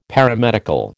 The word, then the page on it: Paramedical: American English